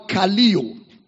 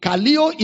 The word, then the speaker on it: Kaleo: And that's the word Kaleo. Kaleo is spelled as K-A-L-E-O.